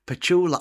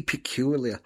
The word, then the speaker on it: Petula: I want to finish the second hour of the show and say ta-ra with, I can never say her name, Petula, I always say peculiar, Petula Clark, Downtown.